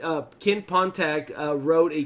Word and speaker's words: Pontac: Uh, is that how you say his name? Pontac? Pontac? – Mm-hmm.